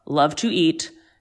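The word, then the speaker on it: Luv 2 Eat: And then I always go to, and I'm going to spell it for you, L-U-V, the number 2, E-A-T, Thai Bistro. Love to eat. It's so delicious.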